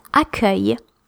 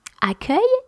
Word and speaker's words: accueil: Another word that is very tricky to know how to pronounce when you see it written, it's accueil. Accueil, A-C-C-U-E-I-L, accueil.